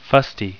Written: fusty

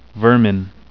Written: vermin

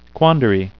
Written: quandary

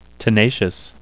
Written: tenacious

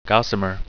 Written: gossamer

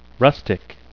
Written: rustic

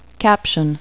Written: caption

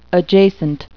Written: adjacent

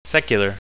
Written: secular

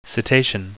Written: cetacean

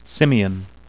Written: simian

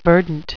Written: verdant

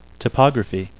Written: topography

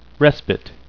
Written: respite

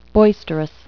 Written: boisterous

